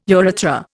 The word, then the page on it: urethra: Indian